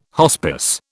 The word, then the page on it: hospice: British English